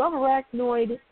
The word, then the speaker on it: subarachnoid: The actress, who said that acting has always been her dream, was diagnosed with a subarachnoid. – Arachnoid. You pronounced it right the first time.